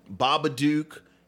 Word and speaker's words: Babadook: Babadook, Get Out.